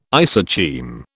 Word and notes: isocheim: British English

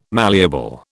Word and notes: malleable: British English

